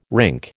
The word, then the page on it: rink: American English